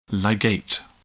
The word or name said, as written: ligate